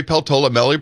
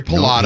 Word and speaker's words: Peltola: And he did not call Mary Peltola Mary Pallotta. – No, he knew how to pronounce her name.